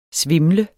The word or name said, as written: svimle